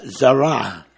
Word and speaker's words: zera: You do have it in the Hebrew, zera, pronounced zara, or zena. 2333 in the Greek and Strong's Concordance. Actually equals seed, S-E-E-D.